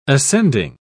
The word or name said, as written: ascending